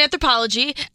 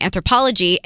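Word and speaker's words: Anthropologie: but you never address that store because you don't know how it's pronounced, it's anthropology.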